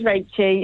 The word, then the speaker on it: Rachie: It's not Rachie, it's Rachie, as in Rachel. – It is Rachie, yeah. – Right. – Yeah, sort of a Rachel.